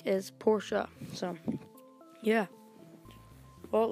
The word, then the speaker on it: Porsche: And I know some people call it Porsche, but the correct pronunciation is Porsche. You kind of add the sound at the end, and it is the true way to say Porsche.